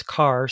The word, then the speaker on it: char: All have the word we would pronounce char, C-H-A-R, as their root.